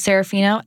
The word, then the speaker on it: Serafino: Okay, so my name, Mia, M-I-A. She goes, Serafino, S like Sam, E-R-A, F like Frank, I-N-O.